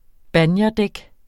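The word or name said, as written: BANJERDÆK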